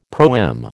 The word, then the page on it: proem: American English